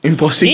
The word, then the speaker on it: imposible: Imposible.